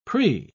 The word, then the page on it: pre-: British English